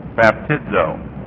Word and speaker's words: baptizo: The last word, baptizo, in our list is actually pronounced baptizo, B-A-P-T-I-D-Z-O.